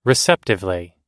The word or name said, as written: receptively